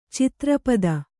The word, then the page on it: ಛತ್ರಪತಿ: Kannada